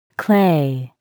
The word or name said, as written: clay